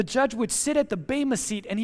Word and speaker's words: bema: The Greek word translated there as judgment seat, okay, is the word bema. You may have heard me say that word last Sunday if you were here. It's spelled B-E-M-A, and the bema is not the seat where the judge sits to render a verdict whether guilty or innocent.